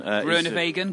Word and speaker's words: Groenewegen: Groenewegen, good pronunciation.